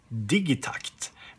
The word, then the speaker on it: Digitakt: One of my favorite pieces of gear is, of course, the Electron Digitakt, or as you say, if you pronounce it a bit more Swedish, Digitakt, because takt actually means something in Swedish.